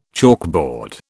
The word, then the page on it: chalkboard: British English